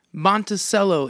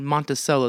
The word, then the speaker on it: Monticello: But I'm smack dab between Indianapolis and Chicago, small rural town, Monticello. They say it wrong. It's Monticello. – Right. – But everybody there says Monticello, Indiana.